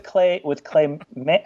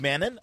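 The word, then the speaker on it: Mann: Well, I work with Clay Man. I always say Clay Man, but you pronounce, he's got two N's in his name, and you pronounce the second N, so it's Clay Mannon.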